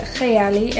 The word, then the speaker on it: Kayali: In Arabic, you actually pronounce it Kayali.